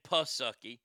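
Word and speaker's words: Psaki: So, Jen Sucky, or I'm sorry, I mispronounced her name, Jen Pusucky, the press secretary, she said that the checks will hit people's bank accounts this weekend.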